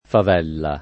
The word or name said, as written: favella